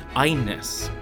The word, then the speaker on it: Agnes: So here is Agnes, or I think they pronounce it in Swedish, Agnes, yes.